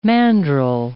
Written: mandrel

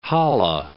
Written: Halle

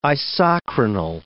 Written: isochronal

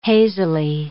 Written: hazily

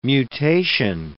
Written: mutation

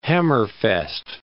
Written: Hammerfest

